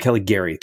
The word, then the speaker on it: Caligari: And then because he talks about a movie in there, the Cabinet of Dr. Hard to pronounce, Callisto. – Dr. Caligari, Cabinet of Dr. Caligari.